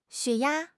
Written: 血压

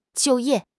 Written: 就业

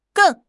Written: geng4

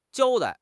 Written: 交代

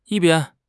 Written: yi1 bian1

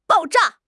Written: 爆炸

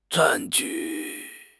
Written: 占据

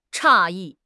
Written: 诧异

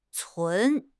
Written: cun2

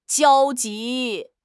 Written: 焦急

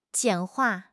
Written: 简化

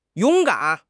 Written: yong3 gan3

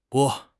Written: 拨

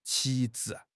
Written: 旗帜

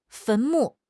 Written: 坟墓

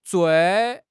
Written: zui4

